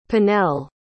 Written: pinnel

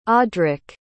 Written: audric

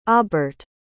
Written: Obert

